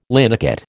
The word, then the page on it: linocut: American English